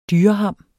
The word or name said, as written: DYREHAM